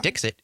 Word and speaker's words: Dixit: D-I-X-I-T. – Dixit. Almost like Dixieland or something. – Yeah. It's like that, but it's a T instead of an E.